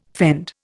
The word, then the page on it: fend: Indian